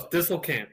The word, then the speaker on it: Disselkamp: Disselkamp, yes.